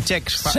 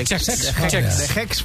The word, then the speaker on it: Cesc: No lo he dicho mal, es Sex. – De Chex. De Chex Fàbregas. que se estrenaba en la competición, ganó 4-1 al Leipzig en otro partidazo.